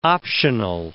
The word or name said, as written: optional